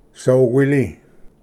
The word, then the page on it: ᏐᏡᎵ: soquili